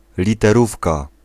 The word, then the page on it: typo: [taɪ.pəʊ]